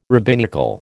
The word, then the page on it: rabbinical: American English